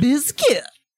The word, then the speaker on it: Bizkit: Okay, so since there is a different spelling than the traditional Bizkit spelling, is it pronounced differently? – No, just Limp Bizkit. – Bizkit.